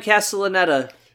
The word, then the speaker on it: Castellaneta: Castellaneta. Is that how you say it? – Dan Yes. Oh, yeah, Dan Castellaneta, voice yeah.